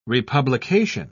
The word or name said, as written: republication